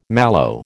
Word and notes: mallow: American English

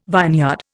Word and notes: vineyard: Indian

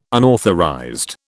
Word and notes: unauthorized: British English

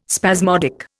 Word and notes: spasmodic: Indian

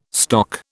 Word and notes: stock: British English

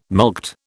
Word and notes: mulct: British English

